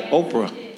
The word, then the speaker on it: Oprah: Did I say it right? Oprah. Yeah, thank you.